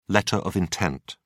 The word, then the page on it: letter of intent: British English